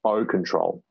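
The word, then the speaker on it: 0-Ctrl: It's O control. – O control. – And also in the web address, it's spelled OH.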